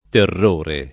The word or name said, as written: terrore